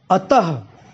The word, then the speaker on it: अतः: जैसे अ और त, अ और त के बाद अगर विसर्ग लगा हुआ यानी कि दो बिंदी आगे लगी हुई, तो उसका उच्चारण हम कैसे करते हैं, अतः, ठीक है, यानी इसका उच्चारण ह की तरह होता है।